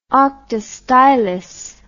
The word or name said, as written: octastylos